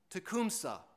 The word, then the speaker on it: Tecumseh: But in the War of 1812, Major General William Henry Harrison defeated the British and the Indian allies, who were led by, I believe the Indian's name is pronounced Tecumseh.